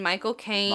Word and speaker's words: Michael Caine: Like you say Mike Cocaine, but you say it in like a British accent.